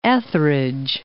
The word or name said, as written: Etherege